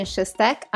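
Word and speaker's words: Susztak: My name is Caitlin Shustak. I am a Hungarian-American. My Twitter handle is K Shustak, which is spelled K-S-U-S-Z-T-A-K, with all the vowels.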